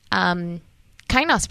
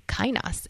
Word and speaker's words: Kainos: And yes, it's pronounced kainos. I've had a lot of people like, how do you even say that word? So it's right. Greek. It's kainos.